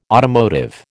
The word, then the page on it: automotive: American English